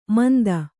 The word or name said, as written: ಮಂದ